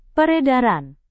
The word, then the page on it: peredaran: Indonesian